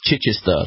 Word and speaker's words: Chichester: I was correcting Joel earlier, it's Chichester. That's how we pronounce it in the UK.